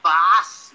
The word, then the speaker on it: Baath: O, só, só lembrando o Baas, né? Se escreve B-A-A-T-H.